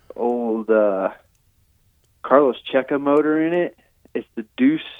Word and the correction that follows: Deus: Cafe, I believe it is. The owner, maybe, possibly. It's sponsored by Deuce, but it's like... Totally, just Deus, you know. Deus Ex Machina is the proper way to say that.